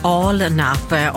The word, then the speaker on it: Alnarp: Ja, det är inte så lätt att veta att det uttalas Alnarp om man sitter i Stockholm.